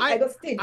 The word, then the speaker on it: Agostini: Yes, Lorenzo. You got it great. You know, I'm gonna be like, I've been saying my last name wrong my entire life. It should be Agostini.